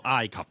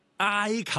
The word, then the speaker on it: 埃及: 埃及嘅。